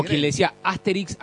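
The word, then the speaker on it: Astérix: Después había gente que tomaba muy literalmente el acento en la E y te decía Asterix. Y, después cuando aprendes francés te das cuenta que no, que es Asterix.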